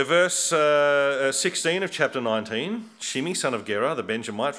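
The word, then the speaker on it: Shimei: Shimi, who Adam told us is how we pronounce his name, Shimi.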